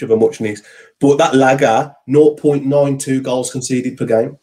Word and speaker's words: Lage: And, okay, yes, a hell of a lot of games managed less, but it's pronounced Lager.